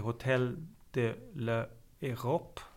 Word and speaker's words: Hotel de l'Europe: Ett av dem har jag besökt, det heter Hotel de l'Europe. Alltså någon sorts franskt uttal, fast det ligger i Bad Gastein i Österrike.